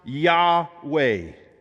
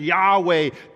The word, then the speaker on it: Yahweh: That word Lord right there are those four letters, YHWH, what we pronounce Yahweh.